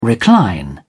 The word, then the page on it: recline: British English